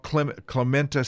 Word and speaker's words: Clementa: Yeah, it's the Clement Pinckney Law, Clementa C.